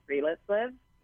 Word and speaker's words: Friluftsliv: For example, in Norway, I studied this philosophy called Friluftsliv. It's spelled F-R-I-L-U-F-T-S-L-I-V.